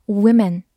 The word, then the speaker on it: women: 复数是把 m a n 变成 m e n，但是注意发音变成 women。